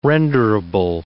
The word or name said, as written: renderable